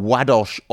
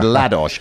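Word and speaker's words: Ładoś: Now, if I was going to pronounce that in my normal way, as you all know, I'm a great mangler of pronunciations, I'd have said Ladosh, because it's L-A-D-O-S, but Roger assures me it's Wadosh. And Wadosh was the Polish ambassador in Switzerland,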